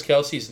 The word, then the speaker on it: Kelce: Did you know that we've been announcing Travis Kelce's name wrong all these years? His last name is pronounced Kelce.